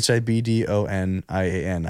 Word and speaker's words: Hibdonian: Hibdonian.